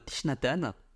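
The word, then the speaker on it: Thich Nhat Hanh: Donc, en français, on dit toujours Tishnatan. Chinatan. Tishnatan en français.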